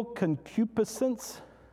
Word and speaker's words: concupiscence: A depraved passion, evil concupiscence.